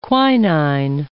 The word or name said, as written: quinine